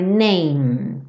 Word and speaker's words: name: Life is Aisha The meaning of her name. Name igual same, sem pronunciar a letra E.